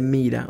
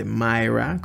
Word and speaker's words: Myra: Eh, pues el mito cuenta que fue un obispo llamado Nicolás de Mira o de Myra, como usted lo quiera pronunciar, que nació en el siglo IV en Antolia, Turquía.